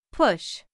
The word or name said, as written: push